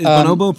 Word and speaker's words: Bonobo: Like, for instance, is Bonobo, do I say it right? Bonobo? Yeah. Is Bonobo present? – He's not on the album.